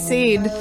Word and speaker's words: Sade: S-A-D-E. Sade. Sade. Sade. – Actually, it's pronounced Sade, Christy.